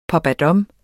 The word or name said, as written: pappadam